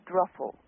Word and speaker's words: Druffel: Ruffle, like a ruffle on a dress. Not Druffel. – Okay. How do you spell? How do you pronounce it? – A druffle. – Druffle. Okay, like a druffle bag.